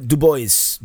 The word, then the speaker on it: Dubois: You would think, since it is a French name, that it would be pronounced Dubois. Mm-mm. Camp River Dubois, that's how they pronounce it, Missourians or Illinoisans.